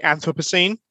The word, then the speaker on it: Anthropocene: Oh, I think that depends on which side of the pond you are, I think. So I say Anthropocene, but that's just because I'm a Brit.